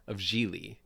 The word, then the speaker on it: Geely: So that was sort of how I tried to make sense of Geely, which rhymes with really, by the way.